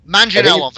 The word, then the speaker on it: Manganiello: Manganiello? – Manganiello, very close, very good.